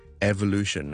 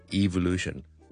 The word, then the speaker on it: evolution: 演化学啦，生物学上嘅演化啦，我哋叫做 evolution 啦，或者 evolution。两个都有人读嘅，自己本身我就读 evolution 多啲，咁但系就两个都非常都多人读嘅。